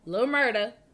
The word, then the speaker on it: Lil Murda: let me say it right. I said murder. Lil Murda.